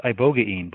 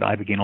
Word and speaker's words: ibogaine: I think it's actually pronounced Ibogaine, believe it or not. I had to give a talk on loperamide toxicity a couple of years ago, and I ended up looking into a weird ECG, and I thought maybe that was the drug, and I was trying to find its pronunciation. I'd been calling it Ibogaine all along.